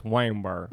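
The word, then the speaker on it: Waynesborough: B-O-R-O is how it's spelled. – Well, if you're here, it's Waynesboro. – Waynesboro, yeah.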